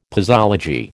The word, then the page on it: posology: American English